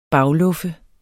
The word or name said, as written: bagluffe